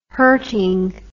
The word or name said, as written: hurting